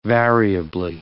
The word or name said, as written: variably